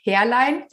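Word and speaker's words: Härlein: Mein Nachname schreibt man H-A-E-R-L-E-I-N im Web, Herlein.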